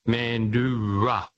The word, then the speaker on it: Mandurah: It's an indigenous name, and it's pronounced Mandurah.